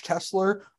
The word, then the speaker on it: Tessler: You can follow me at Josh Tessler.